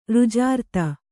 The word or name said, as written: ರುಜಾರ್ತ